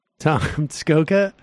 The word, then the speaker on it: Skoka: Rhymes with Lee Iacocca. – Tom Skoka.